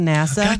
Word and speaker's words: Nassau: But, if my next vacation is to go to NASA, Bahamas, right away I was saying Nassau, because I didn't know it was pronounced NASA.